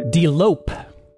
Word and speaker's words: delope: Delope. Delope. That's spelled D-E-L-O-P-E.